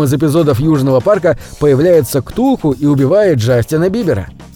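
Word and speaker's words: Cthulhu: А у группы «Металлика» есть песня 1984 года под названием «Зов Ктулху» хотя на самом деле имя самого известного мифического существа Лавкрафта надо правильно произносить примерно как «хлул-лу». Об этом рассказал сам автор мифов.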